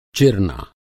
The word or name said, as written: चिरना